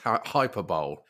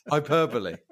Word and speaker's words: hyperbole: If we are playing Quickly Kevin Bingo, can I say that it's not pronounced hyperbole? – How is it pronounced? – Hyperbole. – Flipping egg. Not going to lie. – I've been getting that wrong my whole life. Is it hyperbole? – Yeah.